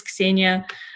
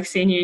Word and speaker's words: Xenia: And well, actually, a funny story is that my name is Xenia, but I actually don't go by Xenia anymore because my name is pronounced Xenia here, but it's totally fine.